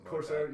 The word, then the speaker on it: Corsair: They were actually just bought out by Corsair. Is that how you say it? – Corsair.